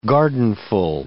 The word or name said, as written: gardenful